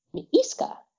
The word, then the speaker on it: Miiska: It's like M-I with the umlaut. So you pronounce it twice. It's a double I sound. Miiska, which is cute.